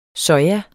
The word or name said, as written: soja